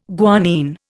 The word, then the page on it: guanine: Indian